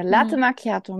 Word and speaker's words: Latte Macchiato: Latte Macchiato. Das hast du schön ausgesprochen.